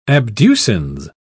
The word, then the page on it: abducens: British English